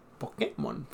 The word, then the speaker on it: Pokémon: Pokémon, este, lleva acento. Lleva acento en la E. Pokémon. porque es Pocket Monsters.